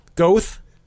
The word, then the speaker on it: Goethe: One day, a desperate Goethe. Is that how it's pronounced? – Yes.